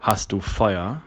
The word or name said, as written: Feuer